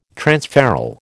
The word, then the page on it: transferral: American English